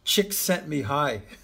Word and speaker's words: Csikszentmihalyi: Peter Sieg, and I don't even know how to pronounce this other guy's name, the guy that created Flow Csikszentmihalyi. – Oh, that's a terribly hard name. Took me years. Yeah. Mikhail Csikszentmihalyi.